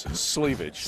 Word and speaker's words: Sleevage: And it was so funny because our man, I keep saying Slavage, right? – And he's like, it rhymes with cleavage. It's sleevage.